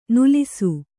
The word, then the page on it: ನಲಿಸು: kannada